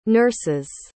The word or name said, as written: nurses